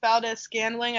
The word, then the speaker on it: Valdes-Scantling: The second-year receiver, Marquez Valdes-Scantling, I think that's how you say it.